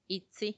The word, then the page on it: itsi: Macuiltianguis